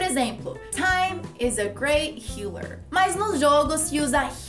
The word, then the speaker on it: healer: Por exemplo, hella. O que, é hella? Ou hila. What is that? Hila, na verdade, é a palavra healer.